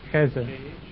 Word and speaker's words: Khidr: Khezr is spelled K-H. Khezr. Or Khezr. K-H-I-D-R.